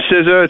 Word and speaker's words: SZA: Well, it is Maroon 5 and SZA.